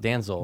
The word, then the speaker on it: Denzel: But oh, it's D-E-N-Z-E-L. But he goes, no, it's pronounced Danzel.